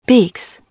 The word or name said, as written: beaks